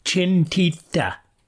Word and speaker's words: Cintita: In international Pali, pronounced Chintita.